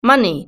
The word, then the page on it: money: British English